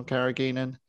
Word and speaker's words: carrageenan: I think, I've been doing a bit of digging on carrageenan. It is a hard G. I found that out at least.